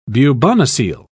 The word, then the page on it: bubonocele: British English